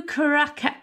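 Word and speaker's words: curaçao: And I've decided that the ingredients of Matt's cocktail is absinthe, blue curacao, how do you say it? Curacao. Blue curacao and champagne.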